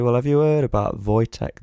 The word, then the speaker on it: Wojtek: It's spelled Wojtek, but you pronounce it Wojtek.